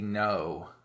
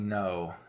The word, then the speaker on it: Ngo: Ngo is spelled Ngo. So there's an N, a G, and an O, but the G is silent, I guess.